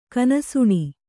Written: ಕನಸುಣಿ

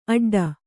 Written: ಆಟಿಡು

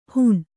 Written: ಹಣಿ

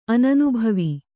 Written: ಅನನುಭವ

